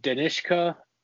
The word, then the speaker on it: Danishka: It's like Danishka Esterhazy, and it's Danishka, then last name Esterhazy. So Danishka Esterhazy is what I probably would have went with.